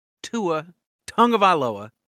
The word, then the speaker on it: Tua Tagovailoa: Speaking of lowest of lows, this week under review, we're throwing Tua Tagovailoa right under the bus.